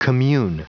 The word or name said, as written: commune